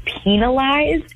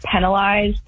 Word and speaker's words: penalized: I have never in my life heard anyone pronounce it penalized. And every time you say it, it really throws me off because you really put a hard emphasis on the peen part of it.